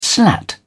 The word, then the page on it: slat: British English